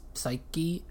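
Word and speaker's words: Seiki: Psyki. S-E-I-K-I. I'm pronouncing that the Japanese way, but it's a Chinese word, so it's probably wrong.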